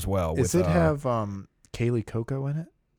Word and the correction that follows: Cuoco: Yes, uh, Cuoco. I think that's how you say your last name.